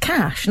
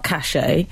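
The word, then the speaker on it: cache: It's pronounced cash, not cachet. I know it's spelt cash. With an E on the end, but this is simplified for you.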